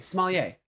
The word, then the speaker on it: sommelier: Sommelier.